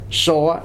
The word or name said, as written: soa